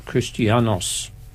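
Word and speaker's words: Christianos (Χριστιανός): In Greek, I believe you pronounce the word Christian as Christianos, and it means anointed in Christ.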